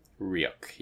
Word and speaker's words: Ryuk: And then does the hospital try to flip back over and go after Ryuk? Is that how you say it? Ryuk? – Ryuk, yeah.